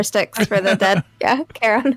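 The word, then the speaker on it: Charon: Is that am I pronouncing that right? Sharon. – Karen. – Karen. Yeah.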